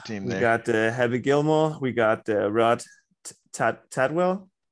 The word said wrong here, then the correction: Tidwell: Is that how you pronounce it? – Tidwell.